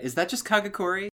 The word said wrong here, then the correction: Kakegurui: I should ask Kawaii Disappointment. They've watched that show. – Yeah, Kakegurui? – I've been pronouncing it wrong every time.